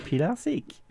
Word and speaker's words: Pilarski: We have Delphine Pilarczyk.